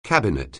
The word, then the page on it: cabinet: anglais